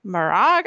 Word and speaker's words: Maragh: This is really laid out in the decision of the New York Court of Appeals in People versus Marag, I think it's pronounced. It's spelled M-A-R-A-G-H.